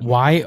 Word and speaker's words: Yomiuri: He was 35 for the Rays, 55 for Oakland, 55 for the Angels, 55 for the Yankees, and then 55 to the Yomiara Giants. – The Giants? – I butchered that, but yeah. – Well, considering it ends in an I and not an A. – Yomiya. – It's Yomiri.